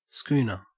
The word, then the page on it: schooner: Australian English